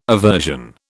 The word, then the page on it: aversion: British English